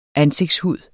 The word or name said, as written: ansigtshud